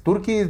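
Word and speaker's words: Türkiye: Bueno, Turkey es como se escribe en inglés, pero ahora es Turki, no pronuncia igual, güey, pero ahora tiene una diéresis.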